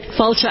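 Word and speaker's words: Fáilte: The Tourism Development Office called, how do you pronounce that in Irish? That's this Gaelic, right? – Falta Ireland. – Falta Ireland. That's Falta. – Gaelic. Falta. – Yeah, close.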